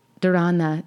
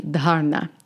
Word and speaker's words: dharana: So it's not, I used to think it was dharana, dhyana, samadhi, but it's actually dharana.